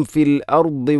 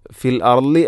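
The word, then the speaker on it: الأرض: ഫിൽ അർദി ദാദ്, ഫിൽ അർലി അല്ല, ഫിൽ അർലി അല്ല, ഫിൽ അർദി. അതുപോലെ തന്നെ ഫിൽ അർദി എന്ന് പറയരുത്, ഫിൽ, ഫിൽ അ, ഫിൽ അർദി.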